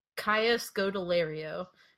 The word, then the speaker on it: Kaya Scodelario: Kaya Scodelario. And it's spelled exactly how it sounds. K-A-Y.